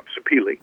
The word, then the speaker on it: sapele: I'm never going to say it correctly, sapele or something like that? – Yeah, sapele.